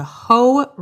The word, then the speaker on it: Hoh: Reason number one that you need to go to the Olympic National Park is the Hoh Rainforest. That's spelled H-O-H.